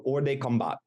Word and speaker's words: hors de combat: So hors de combat, I believe is how you pronounce it. It's a French term.